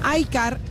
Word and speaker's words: iCaur: iCar, y se escribe iCaur,